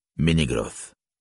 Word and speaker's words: Menegroth: Il en va de même au milieu d'un mot où « e » se prononce toujours, comme dans « Celeborn »,« Menegroth ».